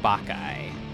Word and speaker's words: Bacchae: So again, we're called Bacchae, B-A-C-C-H-A-E. And now you know how to pronounce it too, Bacchae.